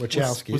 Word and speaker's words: Wachowski: Wachowski. – Wachowski siblings. – Not the Wachowski. Mike Wachowski. – For Jupiter Ascending. Not at least you got the film name right. Well done. – The Wachowski siblings for Jupiter Ascending.